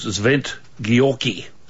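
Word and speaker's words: Szent-Györgyi: By a gentleman called Zvent Gyorky.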